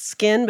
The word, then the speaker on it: SKKN: I understand it's pronounced skin, but it's spelled S-K-K-N, all caps.